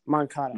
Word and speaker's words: Moncada: And then next, Yoan Makanda versus Max Muncy. – Yoan Makanda. – How do you say his name? What? Moncada.